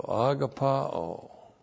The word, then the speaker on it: agapao: So agapao. A-G-A-P-A, long O. Agapao.